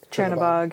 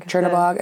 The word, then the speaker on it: Chernabog: Chernabog. – Chernabog.